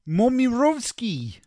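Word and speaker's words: Momirovski: Paul Momirovsky. – That's not bad. Mm. I was just going to go Momirovsky, but yeah, I think the accent works better.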